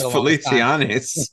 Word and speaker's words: Feliciano: And correct me, you might have to correct me on the pronunciation of the name, but Feliz Navidad by Jose Feliciano is what I got when I didn't even know who sang it. – It's Feliciano.